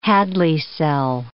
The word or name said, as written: Hadley cell